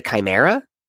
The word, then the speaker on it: Chimera: I think it's the Chimera. That's how you pronounce it.